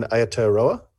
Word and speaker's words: Aotearoa: Speaking of Maori pronunciation, you did drop in an Aotearoa. And I thought it was a pretty good save because I thought, this could be, but you did it well.